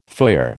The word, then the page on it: foyer: American English